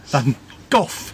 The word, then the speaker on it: Van Gogh: Van Gogh. Remember how to say it. – You got a bad cough. – sounds like cough, not like go. – Go. – No, not go. Cough like cough.